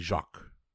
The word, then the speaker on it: Jacques: What series of British comedies always featured Charles Hawtrey, Kenneth Williams, and Hanny Jacques? J-A-C-Q-U-E-S. I am not even going to pretend I can speak or pronounce French. – It's Jacques is usually how that's pronounced.